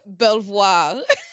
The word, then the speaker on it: Belvoir: So it's spelled B-E-L-V-O-I-R. And voir means to see. So, and bel means like pretty. So something pretty to look at. I don't know. But Belvoir.